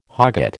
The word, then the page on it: hogget: American English